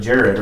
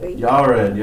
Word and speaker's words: Yared: It looks like Jared, right? – Yeah. – How would you say it in Hebrew? How does, Yared, yeah, very good.